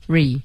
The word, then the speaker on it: hurry: 而这个词呢是 her hurry，对，没有 n 的音，hurry，